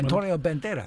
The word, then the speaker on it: Antonio Banderas: How do you pronounce that? – Antonio Banderas. – That's the one.